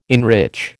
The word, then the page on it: enrich: American English